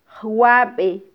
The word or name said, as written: jwabe